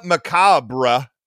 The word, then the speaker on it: Macawber: One thing I just noticed, it's pronounced macabre, which is like if you sort of did a bad job of pronouncing the word macabre, but it's spelled M-A-C-A-W-B-E-R. So it is correctly pronounced.